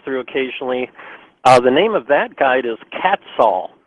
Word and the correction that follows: Quetzal: And it's actually pronounced, now Tim is really funny here because we have differences in how we pronounce things, but it's Quetzal, which okay.